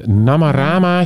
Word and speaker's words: Namarama: Maar we zijn onlangs hebben we een kijkje genomen bij de Namarama show. Zeg ik het goed? – Ja, volgens mij wel.